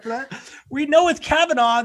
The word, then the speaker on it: Kavanaugh: there's no G. Kavanaugh. – Kavanaugh. Yes, there is a G. Silent G, but whatever.